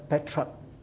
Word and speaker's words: Petrarch: In English, we'll pronounce him as Petrarch, you see.